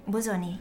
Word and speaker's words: Bosoni: How do you pronounce it? Bosoni? – Bosoni, yes, it's correct.